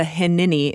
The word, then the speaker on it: hineni: And I hear the hineni. Is that how you say it? Hineni? – Mm-hmm.